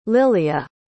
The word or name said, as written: lilia